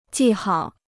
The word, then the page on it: 记号: jì hao